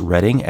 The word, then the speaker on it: Reading: It is pronounced Reading, as in Reading, Pennsylvania, even though it's spelled Reading.